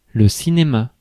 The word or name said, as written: theatre